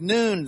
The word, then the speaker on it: noon: And when it says noon there, it looks like none, but no, it's pronounced noon.